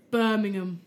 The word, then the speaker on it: Birmingham: We can accept, but like, if you want to be a true local, Birmingham. – Birmingham. Birmingham.